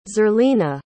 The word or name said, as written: zerlina